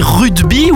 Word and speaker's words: rugby: À la base, c'est un mot qui est anglais, c'est le rugby. Donc on fait un G avant. Mais en Occitanie, on parle de rugby.